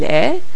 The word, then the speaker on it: est: Now you'll notice for the most part, when I say il est, which means it is or he is, il est, you don't hear the T at the end of est, even though it's spelled E-S-T, except for when the following word begins with a vowel, like in the case of numéro 2.